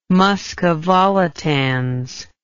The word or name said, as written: musca volitans